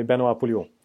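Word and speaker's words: Pouliot: De har också eh, de skrev kontrakt med Pol Poloa. Polo, nej? Polio. Eller ja, Polio. – Uttalar det ju svårt men det är Benoit Polio.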